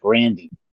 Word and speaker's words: Grande: my grandfather pronounced it Grandy." So it just comes to show you that everybody else pronounced it Grande because it's, you know, Grande instead of Grandy.